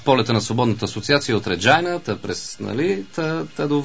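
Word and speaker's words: Regina: От Реджайна. – От Реджайна, както ние наричаме. Пък Шкумбата правилно ни поправи, че е Реджина. Или как беше произношението? – На български се изписва Регина.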